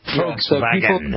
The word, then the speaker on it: Volkswagen: Volkswagen. The V is an F. Volkswagen.